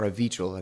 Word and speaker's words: Avichal: This one is uh, by Avikal Garg, and or Avichal, I think is actually how you pronounce it.